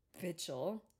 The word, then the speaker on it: Bichel: Andreas Bichel. I'm gonna just say it like that. – That's how it was pronounced. – That's how they said it. Okay. It's B-I-C-H-E-L.